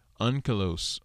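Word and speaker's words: Onkelos: Unkalos. Unkalos. Slika. So you pronounce it unkalos. Like U-N-K. Even though it's spelled O-N-K.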